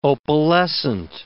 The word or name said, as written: opalescent